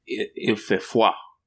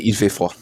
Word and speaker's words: il fait froid: So definitely, il fait froid. Did I say it right? You speak French. – Oui, c'est il fait froid.